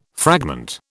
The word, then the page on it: fragment: British English